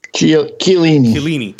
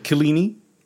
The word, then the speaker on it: Chiellini: Now, it'll get more contentious because of Wild Ricky Pooja was making those comments Chiellini. Or how do you say his name? – Chiellini. – Chiellini.